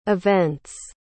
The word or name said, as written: events